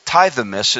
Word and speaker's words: tithemi: Now the word there appointed, I'll give it to you, it's tithemis. It's kind of an interesting way to spell it. It's T-I-T-H-E-M-I. Tithemis. Or tithemi. It's T-I-T-H-E-M-I. Like tithe. Tithemi.